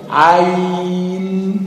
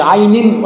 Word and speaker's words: عين: تنطق كذا عين بدون تشديد الياء ولا كسرها، مش عين ولا عين ولا عين، ما تشددش الياء النون والياء نفسها لا تشدد، تقول ما تقولش عين عين، لا غلط، في بعضهم بيقرأ عين عين، لا عين، الياء ساكنة ممدودة بدون تشديد وبدون بدون ايه كسر، لا تكسر ولا تشدد.